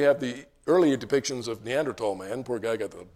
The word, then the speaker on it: Neanderthal: So Neanderthal is how it is pronounced. It's the Neander Valley, and Tall, T-H-A-L in German, means valley.